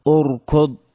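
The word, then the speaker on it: اركض: الهمزة ننطقها بهمزة مضمومة أو ثم مع الحرف الساكن أركض.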